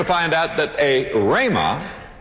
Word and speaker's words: rhema: But there's another word that is translated word, and it's rhema. R-H-E-M-A. Rhema. Or rhema. However you choose to pronounce it.